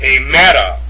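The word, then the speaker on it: Hemera (ἡμέρα): It's pronounced in the Koine, Hemera. Hemera. And how this is written in the Koine is eta, mu, epsilon, rho, alpha, with a rough breathing mark over the eta and an accent on the epsilon. Hey, meta. Hey, meta. Hey, meta. That's how you would pronounce that.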